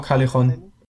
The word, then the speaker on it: Callejon: Und ähm, ich habe diesmal mir ausgesucht ähm, von Callejon, glaube ich, spricht man sie aus, ne? Oder? – Mhm. – Weißt du das? – Genau, Callejon.